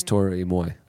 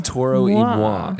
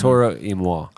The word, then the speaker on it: Toro y Moi: In Relevant Recommends, I recommended an album that I have been playing nonstop, and I said it was Tori Moi. That is a mispronunciation. – Mm. – The last word is M-O-I. Pronounce moi. – Oh. – It's Toro y moi, and I've been saying it wrong this whole time.